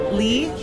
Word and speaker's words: Lee: So my name, Lee, L-E-E, and the first name is Sukyin, and that's spelled like bookbin.